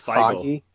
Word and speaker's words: Feige: Kevin Feige, or however you pronounce it. His name is pronounced Feige.